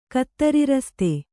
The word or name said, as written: ಕತ್ತರಿರಸ್ತೆ